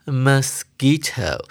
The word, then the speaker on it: mosquito: 首先，我们看到第一句，Hi there，大家好，I'm a mosquito。注意到这个字的念法，它的第一个音节呢是清音节，它的 o 念呃，所以 mosquito 它的 Q U 念的是 K 的发音。